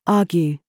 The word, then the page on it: gig: British English